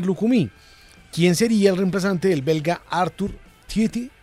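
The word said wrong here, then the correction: Theate: ¿Te pronuncio así, Guillo? – Sí, Arthur Teate. – ¿Teate? – Sí.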